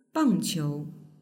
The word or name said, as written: bangqiu